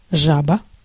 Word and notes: жаба: /ˈʐabə/

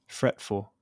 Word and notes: fretful: American English; /ˈfɹɛtfəl/

